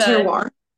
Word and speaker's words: terroir: So the term that you just used, the tar I'm gonna butcher it. What is it? The Terroir.